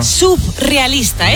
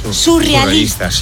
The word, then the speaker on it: surrealista: Y por otro lado, también decimos mal surrealista. Muchas veces la gente dice, y esto me pone muy nerviosa, subrealista. Es surrealista.